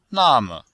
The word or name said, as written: na4me5